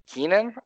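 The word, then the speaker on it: Keiynan: But I really like Keenan. Is that how you say it? – That's how you say it. It's spelled differently.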